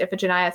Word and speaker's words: Iphigenia: I think there are multiple ways to pronounce it. I always said Iphigenia in my head, but uh, all of my theater friends say that in theater circles it's pronounced Iphigenia. So I was like, I'll just go with that.